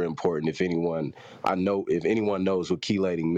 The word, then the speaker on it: chelating: Functional hydroxyl groups of flavonoids mediate their antioxidant effects by scavenging free radicals and or by chelating, I don't know how to pronounce that, chelating metal ions. – Chelating. – Which is, say it again.